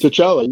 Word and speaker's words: T'Challa: And, you know, when they're doing that ceremony and he's going into the dream and he comes, you know, it's T'Challa. I can't pronounce his name, but it's T'Challa. You said it right. – Okay. I said it right.